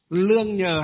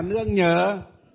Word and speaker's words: nương nhờ: Lương nhờ hay là nương nhờ? Nương nhờ chứ không phải là lương nhờ.